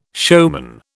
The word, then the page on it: showman: British English